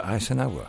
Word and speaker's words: Eisenhower: Se pronuncia Eisenhower.